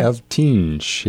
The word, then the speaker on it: Eltinge: Eltinge, yeah.